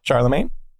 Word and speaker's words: Charlemagne: Charlemagne? – Yeah. You know the correct pronunciation on that is Charlemagne, but everyone thinks it's Charlemagne.